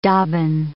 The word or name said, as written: dobbin